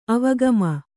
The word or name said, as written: ಅವಗಮ